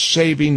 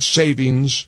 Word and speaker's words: saving: Daylight saving, not savings. You always want to say savings, but it's saving apparently.